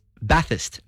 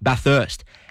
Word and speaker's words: Bathurst: He says it's Bathurst, not Bathurst. And Kevin says Bathurst, there's only one H in Bathurst. He's tuning in from Barara. So thank you to Jim and Kevin for your texts. That's two in favour of Bathurst being the right pronunciation.